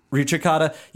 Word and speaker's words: Ricercata: That is probably a very Midwestern pronunciation of that. – Musica Ricercata. – Ricercata. Yeah. See, you're or as being...